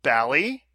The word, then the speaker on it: Bally: It's pronounced Bally. Because, Bruce, do you know where that name comes from? – Ballyhoo. – Ballyhoo, not Ballyhoo.